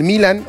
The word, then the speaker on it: Milán: Alexis Vega de Toluca, Armando La Hormiga González de Chivas, Guillermo Martínez de Pumas, Santiago Jiménez de Milán, exactamente, de Italia, del Milan, o Milán, como lo quieran pronunciar, Raúl Jiménez del Fulham, de Inglaterra.